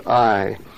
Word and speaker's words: Ai: Sometimes it's pronounced Ai, but I think I'm just going to pronounce it as Ai.